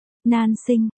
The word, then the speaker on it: Nam Hạnh: Nan sinh, Nam Hạnh, phát âm là, Nan sinh, còn khó là, Nan, may mắn là, sinh,